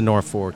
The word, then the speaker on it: Norfolk: Say whatever the nor fuck you want. – Yeah, all right. It's the Norfolk. – Oh, I was pronouncing it the Virginian way.